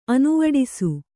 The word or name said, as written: ಅನುವಡಿಸು